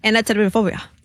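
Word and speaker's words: Anatidaephobia: A-N-A-T-I-D-A-E. – Anatidaphobia. – No, that's exactly how you say it.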